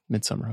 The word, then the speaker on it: Midsommar: Midsommar.